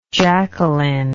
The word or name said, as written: jackelyn